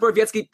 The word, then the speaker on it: Borowiecki: By the way, if you have no idea who we're talking about, his name is spelled Borowiecki, B-O-R-O-W-E-I-K-I or something. Anyways, like, it's pronounced Borowiecki. Trust me, This one, I know we don't say them all right. We're saying this one right, okay?